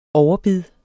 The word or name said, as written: overbid